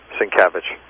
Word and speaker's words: Sienkiewicz: Sienkiewicz.